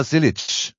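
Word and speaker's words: Zilisch: Erstens, er spricht sich aus Connor Zielisch. – Okay. – So spricht man ihn aus.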